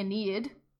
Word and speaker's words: Aeneid: This time it's the Aeneid.